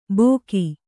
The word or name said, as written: ಭೋಗಿ